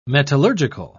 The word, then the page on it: metallurgical: British English